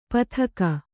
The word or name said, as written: ಪಥಕ